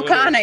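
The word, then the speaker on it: Khanna: Back in October when Mnuchin was offering this $1.8 trillion deal, you might remember friend of show Rohana at the time. – Did you say Rohana? – Is it Rokana? – Rokana, yeah. You sounded like Amy Goodman pronouncing.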